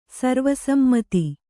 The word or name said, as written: ಸರ್ವಸಮ್ಮತ